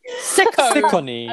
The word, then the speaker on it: Ciccone: C-I-C-C-O-N-E. – C-I-C-C-O-N-E. Oh, Sicone. – I think it's Sicone. – You went, you know when you're sick of a cone? – Sicko.